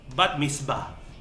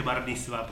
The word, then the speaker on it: Bat Mitzvah: La película que ya está en el catálogo de Netflix es No estás invitada a mi Bat Mitzvah. Creo que lo dije bien. – Sí. – No dije Bar Mitzvah porque no se dice Bar Mitzvah. Pero nosotros a veces lo decimos así.